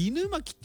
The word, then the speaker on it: 犬巻: あ、これ犬巻って読むんだ。